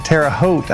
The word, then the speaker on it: Terre Haute: All right, so we're going over to the Midwest now to the Greater Terre Haute Skill Level Tournament that is in Terre Haute, I believe is how you pronounce it.